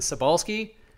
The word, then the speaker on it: Cybulski: James Cebulski at James Cebulski on Twitter. – Can you spell that for them? Because that might be a problem. – James? That feels self-explanatory, but J-A-M-E-S. And then Cebulski? Yes. C-Y-B-U-L-S-K-I. There you go. Think about it like do re mi. C-Y-B-U-L-S-K-I.